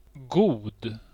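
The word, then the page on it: god: IPA: [guːd]; IPA: /ɡuːd/; Swedish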